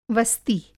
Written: वस्ति

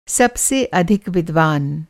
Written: अधिक

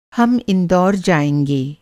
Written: इंदौर

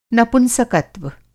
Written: नपुंसकत्व

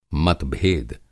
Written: मतभेद

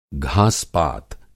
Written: घास-पात

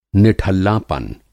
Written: निठल्लापन

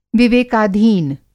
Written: विवेकाधीन

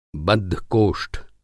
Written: बद्धकोष्ठ

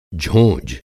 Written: झोंझ

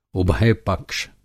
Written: उभयपक्ष

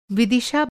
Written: विदिशा

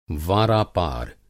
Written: वारापार

